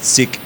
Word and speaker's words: Sikh: And Brian was talking to a Sikh lady, which is not unwell, but Sikh, the religion. – S-I-K-H. – S-I-K-H, which I discovered in that episode is the correct way to pronounce that, which my whiteness was not aware of prior to this.